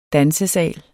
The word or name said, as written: dansesal